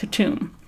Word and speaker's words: KTM: Her brother, or perhaps half-brother, is a big long-haired orange cat named KTM, pronounced Katoom.